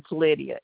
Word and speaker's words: Lydia: My name is Lydia.